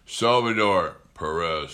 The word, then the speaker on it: Salvador Perez: and he can become the he becomes Salvatore Perez. Salvador Perez.